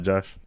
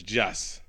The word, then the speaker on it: Joss: Cause not Josh. – He's not Josh. It's Joss. So you got to put the extra T in there. Some the TH in there. Joss.